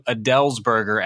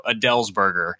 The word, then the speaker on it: Adelsberger: It's actually pronounced closer to Adelsberger, as in like the singer Adele is eating a hamburger. So Adelsberger.